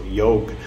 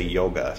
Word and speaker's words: yoga: Or Ashoka, or the A is silent usually. So yog is how you actually say yoga.